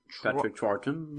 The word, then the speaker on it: Troughton: Patrick Troughton.